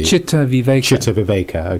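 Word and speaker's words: Cittaviveka: Is it pronounced Chittaviveka? Chittaviveka.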